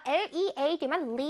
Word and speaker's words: lead: lead라고 원래 발음되는데 여기에서는 lead, 연필심 할때 lead는요, lead는요, 납이란 뜻이거든, 납. 그래서 납은 영어로 lead로 읽어요. 그래서 lead라고 읽기 lead가 lead라고 읽힐 때도 있지만 여기 연필심, 샤프심을 뜻할 때는 lead라고 읽힙니다. 이거 lead라고 하면 안 돼요. lead, lead, pencil lead.